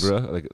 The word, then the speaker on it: livre: Livre, like the Louvre, but like a douche pronunciation.